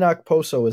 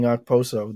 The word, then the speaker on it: Okposo: The K in Okposo is silent, so if we've been saying Okposo this whole time, I just learned.